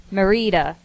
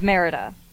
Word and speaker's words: Merida: That was amazing because we were, she emailed in to have it correct us on how to say the protagonist's name. We were saying Merida, yeah. And her name is Merida.